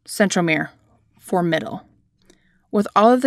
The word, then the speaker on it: centromere: Centromere is the middle point connecting the two sister chromatids. Okay, centromere, and I put emphasis on the M, mere, M as in middle.